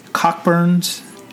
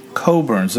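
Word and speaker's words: Cockburn's: The Cockburns. Coburns. – What? – Coburns. The pronunciation is Coburns.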